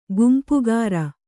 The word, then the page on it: ಗುಂಪುಗಾರ: gumpugāra